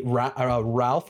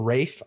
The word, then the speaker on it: Ralph: Ralph or Rafe. I don't know how his name is pronounced. Hey, man, get an easier to pronounce name. It looks like an L. I think it's Rafe.